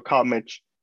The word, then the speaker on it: CalMich: Well, you pronounce it right because how you say it, you know, but if you talk about, you know, on the Hispanic side, of course it's Michoacán, so it's Mitch. So CalMich.